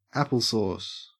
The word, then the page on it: applesauce: Australian English